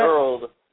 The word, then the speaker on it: EERLD: Earled. – Earled apparel.